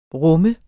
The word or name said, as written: RUMMEDES